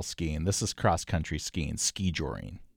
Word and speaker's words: skijoring: The word is skijoring. Skijoring. – And that's how it's pronounced? Skijoring? – Yes. Skijoring. Emphasis on the first syllable.